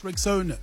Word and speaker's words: Rexona: It's pronounced Rexona, simply strong and easy to remember.